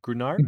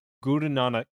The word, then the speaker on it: Guru Nanak: But Guru Nanak. – Guru Nanak.